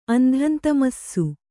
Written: ಅಂಧಂತಮಸ್ಸು

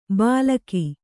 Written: ಬಲಗಿ

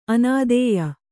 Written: ಅನಾಧೇಯ

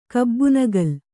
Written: ಕಬ್ಬುನಗಲ್

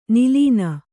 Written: ನೀಲಿನಿ

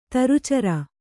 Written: ದುರಾಚಾರೆ

